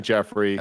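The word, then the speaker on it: Geoffrey: Joffrey. – Which I said Joffrey, but everyone told me it's not. – Yeah, I looked up the pronunciation video for it. It's definitely Jeffrey.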